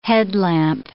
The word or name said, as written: headlamp